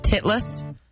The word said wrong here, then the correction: Titleist: How do you say the company name? – It's pronounced Titleist.